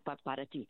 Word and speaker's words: para ti: I go, pot of tea, pot of tea. When I started saying that, then I knew the pronunciation correctly in Spanish, para ti.